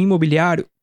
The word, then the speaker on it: imobiliário: Lembre-se de pronunciar os is em imobiliário como i, e não como e in it.